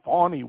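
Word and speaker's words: Fani: Now let's go over to Fannie Willis, or Fawny Willis. Might want to add a W to that name if he wants to pronounce it that way.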